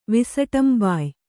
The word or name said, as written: ವಿಸಟಂಬಾಯ್